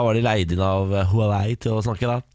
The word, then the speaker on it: Huawei: Det var lanseringsfest for Huawei's nye telefon i går. P90 Pro tror jeg den heter. – Ja, vi har vel nå funnet at det uttales Huawei.